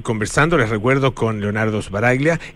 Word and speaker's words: Sbaraglia: Si vos me decís a la italiana, sería Sbaraglia, pero nadie Sbaraglia. Dice así. O sea, Sbaraglia, en italiano se dice Sbaraglia, pero en Argentina es Sbaraglia, y vos lo dijiste mejor que está bien, está perfecto.